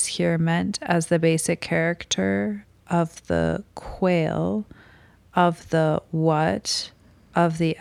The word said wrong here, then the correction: quale: Yeah, I think that's pronounced quale. – Quale, okay. – Yeah, I believe. – Not quail. – Probably not quail.